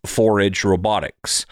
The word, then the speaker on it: Forage Robotics: All right, now back to today's featured conversation with Sean O'Connor of Forage Robotics. And it may look to you like 4AG Robotics because they spell it with the number 4 and then AG, but it is pronounced Forage Robotics.